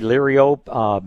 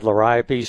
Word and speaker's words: Liriope: Some people say Liriope. My grandfather said Liriope, so I've always said Liriope.